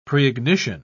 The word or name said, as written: preignition